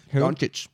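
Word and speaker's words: Dončić: Doncic.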